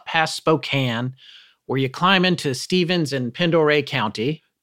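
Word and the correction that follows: Pend Oreille: Pondere. – See, I had to ask Forrest, and you'll know why in a second. Up past Spokane, you climb into Stevens and Pondere County.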